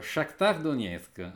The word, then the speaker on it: Shakhtar Donetsk: e ricordo che telefonai al consolato ucraino per farmi aiutare sulle pronunce e mi dissero Shakhtar Donetsk.